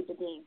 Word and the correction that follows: Bedene: I think it's Bedene. – Bedene.